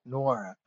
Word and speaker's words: Nwora: Funnily enough, you threw me a curveball and wanted to talk about this year's number 45 selection, Jordan Nwora, is that how you say his name? – Yeah, I'm learning it myself. I believe you had it correct. Nwora.